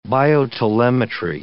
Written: biotelemetry

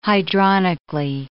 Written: hydronically

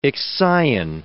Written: Ixion

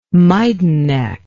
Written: Maidanek

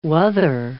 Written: wuther

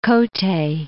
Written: Kotte

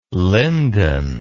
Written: lyndon

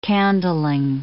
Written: candling

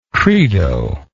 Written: credo

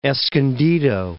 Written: Escondido

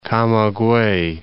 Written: Camaguey

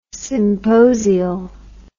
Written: symposial

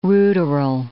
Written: ruderal

